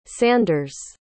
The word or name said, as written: sanders